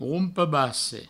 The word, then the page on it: rompe-basse: roumpe-basse; Provençal